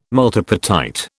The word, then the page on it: multipartite: British English